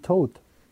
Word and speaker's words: Toad: Na, Captain Todd nicht, aber Captain Toad. Den habe ich schon gesehen, ja. – Der, heißt das nicht Todd? Also Todd, wird das nicht Todd ausgesprochen? Toad. Todd. Ah, okay.